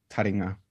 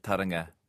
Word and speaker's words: taringa: And I should say, by the way, I actually thank you for the compliment, but I mangled that word, and I think it's because from singing You Are My Sunshine at school, where it's whispered in my taringa, but that's not a long A at the stop of taringa.